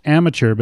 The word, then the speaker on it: Amattr: the the it's pronounced amateur, but it's actually spelled A M A T T R, and I'll have that.